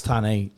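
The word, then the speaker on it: Tarneit: Because the Brownlow goes on at a certain time, and if Dane's stuck in Tarnheit... – It's Tarnheit, Samantha. The T is silent.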